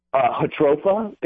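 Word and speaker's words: Jatropha: Hatropha. It's spelled with a J. J-A-T-R-O-P-H-A.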